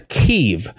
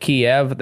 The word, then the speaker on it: Kyiv: It is Kyiv. Even though for the past however long I've been alive, we've been calling it Kiev. That is no longer the case.